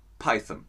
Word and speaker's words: Python: Die Amerikaner, Brian, vielleicht kannst du da helfen, die Amerikaner sprechen Python etwas anders aus mit einem länger gezogenen O. Aber sogar Terry Gilliam hat als US-Amerikaner die britische Aussprache adaptiert und das wäre dann Python. Monty Python. Also das O wird fast verschluckt.